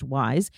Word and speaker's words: Yse: It's spelled Y-S-E, but pronounced Wise.